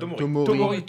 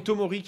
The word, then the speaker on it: Tomori: Mais bon, sachant que euh, ah, comment il s'appelle le défenseur anglais euh, Tomari Non. Euh, Tomori. Tomori.